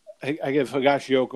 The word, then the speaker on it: Higashioka: And I give Higashioka all kinds of credit as I pronounce his name properly for the first time in 67 games or whatever it's been.